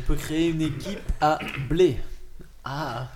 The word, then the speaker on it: Bled: et alors, euh, ça se prononce Bled, mais ça s'écrit B-L-E-D.